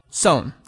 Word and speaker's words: Son: This is just the short one. Well, what's important to know is just, it's what we would consider the long O sound, but just a shortened version of it. O. Son. That's all there is to it.